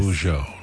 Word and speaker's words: Bijou: Bijou, like Beaujolais, Bijou.